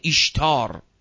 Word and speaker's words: ایشتار: شما در معابد مشرکانه قدیم که در خیلی از جاها بود، معبد ایشتار، معبد ایشتار همون معبد ستاره است که سین رو شین تلفظ میکنن، ایستار یا استار یا ستاره، الفش به خاطر زینته، الف زینت میگن مثلا، که معبد ستاره که ستارگان رو پرستش میکردن.